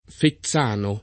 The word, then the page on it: Fezzano: lingua italiana